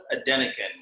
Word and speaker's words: Adenekan: Bio Adenekan. – Okay, well, how do you say your, just say your full name for me, please? – Adebayo Adenekan.